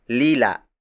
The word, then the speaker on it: lila: The first non-native color is lila